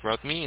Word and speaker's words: Rodney: Okay, my first name is Rodney,